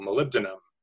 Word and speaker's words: molybdenum: But you actually need the mineral molybdenum for ceruloplasmin. – That's how you say it. I always pronounce it wrong. I'm like molybdenum.